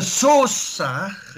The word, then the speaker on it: Sousa: Aber das A wurde gar nicht gesprochen. – Genau, Sous war es eigentlich.